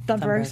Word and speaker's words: Thunberg: Donna Levesser, and I'm sorry if I mispronounced her last name, says, Doctor Who played by Greta Thunberg. – Thunberg.